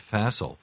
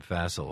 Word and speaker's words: Phacil: How did it come out that the Treasury was working with an outside consulting firm named Facile? I love that name, Facile, although it's spelled P-H-A-C-I-L.